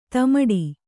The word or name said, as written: ದಿಮ್ಮಿಡಿ